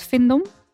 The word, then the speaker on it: Findom: Evil Queen, Dominatrix, Findom. Zeg ik dat goed? Findom?